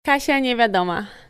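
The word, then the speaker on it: Kasia Niewiadoma: She's actually just told me how to pronounce her name properly. Here it is. – Kasia Nevyedoma.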